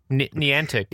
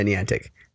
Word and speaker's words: Niantic: Niantic. – Yeah, Niantic.